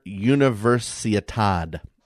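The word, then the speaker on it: Universiade: Frey has been training in his hometown of Oslo, Norway, in preparation for the International University Sports Foundation, which is the FISU, Summer Universitad, is how you say it.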